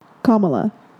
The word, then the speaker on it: Kamala: And correct me if I'm wrong in her name. – Kamala. – There you go.